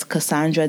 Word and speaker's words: Cassandre: If you aren't following me on Clubhouse, my name is Cassandra Dunbar. Cassandra is spelled C-A-S-S-A-N-D-R-E.